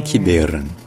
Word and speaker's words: Kiberen: Donc non seulement le E au milieu est prononcé, non seulement il est prononcé E, mais en plus c'est là qu'il est l'accent tonique. En breton c'est bel et bien Kiberen.